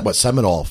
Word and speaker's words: Seminole: Well, I mean, they had Caribbean, they had African, they had Cajun, they had Narragansett, they had Seminole from down Florida.